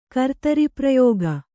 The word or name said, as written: ಕರ್ತರಿಪ್ರಯೋಗ